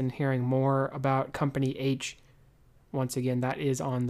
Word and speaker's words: Aytch: Company H, if you are not aware, it is spelled A-Y-T-C-H, is one of the better known memoirs of the Civil War.